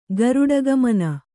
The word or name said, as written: ಗೋರಾಡ್ಗಮನ